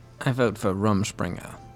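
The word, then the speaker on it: Rumspringa: Rumspringer. – Rumspringer.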